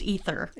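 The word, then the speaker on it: Eythor: Athor Gudjonsson plays Oli. – I think it's pronounced Ether.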